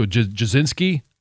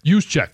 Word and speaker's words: Juszczyk: Jasinski or? – Juszczyk.